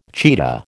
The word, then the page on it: cheetah: American English